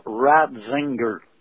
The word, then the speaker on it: Ratzinger: On a Joseph Ratzinger. – Ouais. Hein, ça se prononce comme Kissinger.